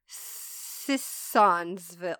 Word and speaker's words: Sissonville: Sionsville. I'm assuming that's how you say it. That's a lot of S's. Nope, I definitely did not say it right. Sissonville. I don't know. S-I-S-S.